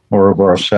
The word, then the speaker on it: Ouroboros: but that uboros is that correct pronunciation? Yeah, Ouroboros.